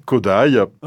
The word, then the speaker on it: Kodály: Alors ça s'écrit Kodály, mais ça se prononce bien Kodály.